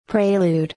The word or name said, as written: prelude